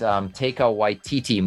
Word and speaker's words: Taika Waititi: So I think what he's done already, I think we'll see, and it's particularly in that in the planned Taika Waititi movie.